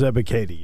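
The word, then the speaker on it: Ebiketie: I liked Arnold Ebikadi. Did I say it right? – It is Ebikadi, yes.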